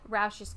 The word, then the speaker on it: Roush: The police started focusing on a Carl Roush, who was 44, and I'm hoping I'm saying that right. R-O-U-S-H. Roush? – Yeah, I would say Roush.